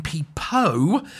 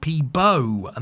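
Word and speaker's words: Peabo: And it was Freeman's difficulty in pronouncing the name Peepo. He kept getting it wrong, and that led to Bryson ending up being called Peebo.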